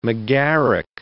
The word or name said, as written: Megaric